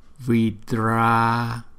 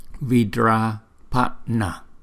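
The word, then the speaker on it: Vidrāpaṇa: Chúng ta đọc là Vidrapana. Vidrapana. Chữ N này cũng phải là đọc từ trong. Chúng ta cũng phải đọc từ trong cuốn họng đọc ra. Vidrapana.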